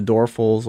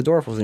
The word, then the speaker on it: Doerfel: People don't even say, hey, we're the Dorfels, and people are like, the what? A door, the door. And then if you look at how it's spelled, it's like Doerfel. So Dorfel, Dorfel. Like, what?